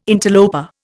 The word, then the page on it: interloper: Indian